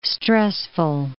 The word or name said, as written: stressful